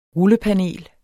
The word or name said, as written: rullepanel